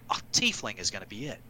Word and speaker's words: Tiefling: And they do have a pronunciation for it, and it is Tiefling. Tiefling.